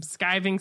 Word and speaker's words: Skiving: And, you know, they had extendable ears there, reusable hangman, skeeving snack boxes, except I think we pronounce them skiving snack boxes.